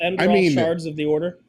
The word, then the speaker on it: Enderal: Enderal? – Enderal? – That sounds right.